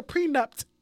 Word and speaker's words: prenup: She over pronunciates everything, but then by over pronunciating, she pronounces it wrong. Like, she kept saying, I can't believe he went to go get a prenup. I'm like, what? There's no T, boom.